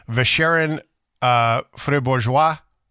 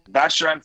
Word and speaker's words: Vacherin Fribourgeois: Swiss Emmental, pine tree cheese, cold cheese, and then there's Vacherin Fribourgeois. I'm guessing. – Vacherin.